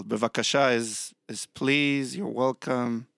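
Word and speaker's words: Bevakasha (בבקשה): Bevakasha. – Well, that Bevakasha. – Was, slow that Bevakasha. One down for me. – Bevakasha. – Bevakasha? – Yes. – Bevakasha? Bevakasha. – Exactly. Bevakasha.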